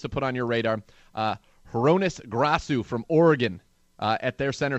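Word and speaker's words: Hroniss Grasu: And from talking to some of the offensive line coaches around this team, it's Hronis Grasu. Hronis Grasu, I've been told the pronunciation is there.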